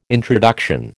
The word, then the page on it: introduction: American English